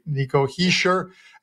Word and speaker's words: Hischier: Pronounced it right, that guys. There's no R in there. Nico Hischier.